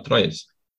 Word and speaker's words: Troyes: Me corrija a minha pronúncia, você sabe como é que fala Troyes? – Troyes. É meu Troyes.